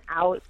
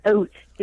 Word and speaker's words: out: If you're going to say the word out, can you say it correctly? It's not oat.